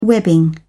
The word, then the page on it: webbing: British English